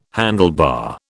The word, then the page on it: handlebar: British English